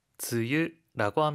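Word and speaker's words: 즈유 (梅雨): 일본어로는 뭐라고 읽죠? – 즈유라고 합니다. – 즈유라고 하죠.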